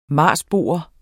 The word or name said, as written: marsboer